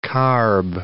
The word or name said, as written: carb